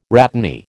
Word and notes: rhatany: American English

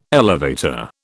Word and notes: elevator: British English

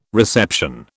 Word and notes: reception: British English

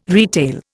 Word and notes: retail: Indian

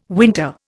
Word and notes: winter: Indian

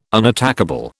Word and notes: unattackable: British English

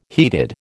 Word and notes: heated: American English

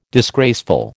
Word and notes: disgraceful: American English

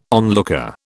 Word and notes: onlooker: British English

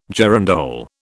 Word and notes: girandole: British English

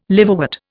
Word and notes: liverwort: Indian

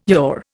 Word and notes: yaw: Indian

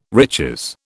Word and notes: riches: British English

